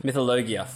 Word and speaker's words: mythologia: Mythologia. – Mythologia. – Instead of logie, it's logia.